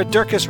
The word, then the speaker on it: Durkis: Stu Dolnick, Sarah Durkis rhymes with circus, Kelly Cameron, Wayne Pedersen, Leanne Peterson, Janet Selby, Claire Adams, and Tom Fronzak for your generous support of the podcast.